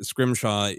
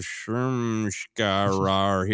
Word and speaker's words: Scrimshaw: But growing up in the Midwest, there were so many like Schroeders and other names that had an H that teachers saw an H where there wasn't. And so I would every year at school be as Joseph Schrimshkarar here.